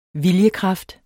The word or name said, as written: viljekraft